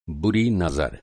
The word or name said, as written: नज़र